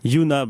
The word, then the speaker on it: Yuna: T'as aussi joué dans Projet M, et plus récemment, t'as joué dans Invisible, film de Juna Schiff, si je le prononce comme il faut. – Presque, vas-y donc. – Yuna,